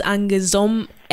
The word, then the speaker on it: Anguesom: My Instagram name right now is Anguesom X. It's A N G U E S O M X. I put the X there because you know the O is silent.